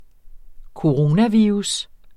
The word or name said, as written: coronavirus